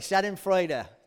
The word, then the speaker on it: Schadenfreude: Schadenfreude. Okay, Schadenfreude. Yeah, that's how you say it.